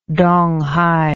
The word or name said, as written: donghai